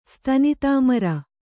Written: ಸ್ತನಿತಾಮರ

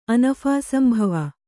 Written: ಅನಫಾಸಂಭವ

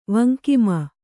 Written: ವಂಕಿಮ

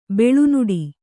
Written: ಬೇಳುನುಡಿ

